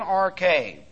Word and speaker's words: arche: In arche, and this is the preposition in, and you have to deal with an understanding of prepositions, A-R-C-H, long E, in arche.